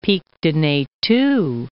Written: Pic de Nethou

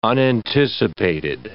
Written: unanticipated

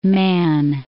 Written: Man, Isle of

